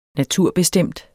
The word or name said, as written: BESTEMMES